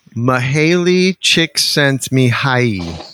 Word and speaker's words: Mihaly Csikszentmihalyi: Mihaly Csikszentmihalyi is the author of Flow.